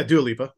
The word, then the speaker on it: Dua Lipa: Dua Lipa, is that how you say her name? – Yeah, Dua Lipa.